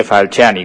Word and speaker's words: Falciani: Pues te llamamos porque nos habéis mandado una nota de prensa por la libertad de Hervé Falciani, no sé si lo pronuncio bien. – Sí, Falciani sería.